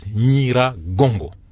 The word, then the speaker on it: Nyiragongo: Alors on prononce Nyiragongo et Nyamulagira.